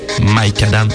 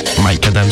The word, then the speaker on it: Micadam: Mike Adam, c'est comme ça que vous prononcez. Mike Adam, si si.